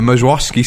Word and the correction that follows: Meyerowitz: stories, is that how you say it? – Oh, the Meyerowitz stories.